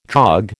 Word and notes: dog: American English

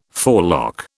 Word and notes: forelock: British English

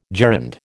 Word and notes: gerund: American English

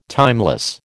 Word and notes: timeless: American English

